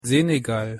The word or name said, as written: Senegal